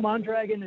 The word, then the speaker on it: Mondragon: Nope, Mondragon is it. It's all good. I could roll my R's and sound really fancy, but I'll just keep it American.